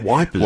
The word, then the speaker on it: Ypres: And they were going to break out of their base in the Belgian town of Ypres, spelt Y-P-R-E-S. Or the British called it Wipers, didn't they? – Wipers.